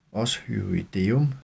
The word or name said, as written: os hyoideum